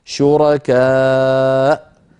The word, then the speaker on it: شركاء: المثال الثالث في هذه اللوحة شركاء شرعوا في سورة الشورى، أيضا الواو هنا كرسي للهمزة والألف التي بعدها زائدة في الخط لا تنطق لا وصلا ولا وقفة، فإن وقفنا عليها نقف على همزة ساكنة هكذا شركاء.